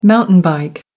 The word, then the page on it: mountain bike: American English